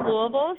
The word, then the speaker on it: Louisville: Well, I'm pretty sure you said Louisville, so that's the way to my heart is pronouncing it correctly.